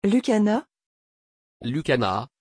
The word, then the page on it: Lucjana: French